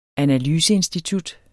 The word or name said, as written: analyseinstitut